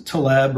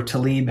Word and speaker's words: Taleb: This is a concept that comes from Nicholas Taleb or Taleb, however he or you choose to pronounce it.